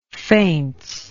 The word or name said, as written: feints